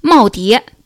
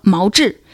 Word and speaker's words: 耄耋: 在节目中出现了一个相对较为复杂的汉字“ 耄耋”，但陈果却读成了“ 毛志”，一时激起千层浪。